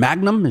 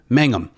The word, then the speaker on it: Mangum: And is it Jonathan Magnum? Is it Mangum? How do you say that? – Mangum.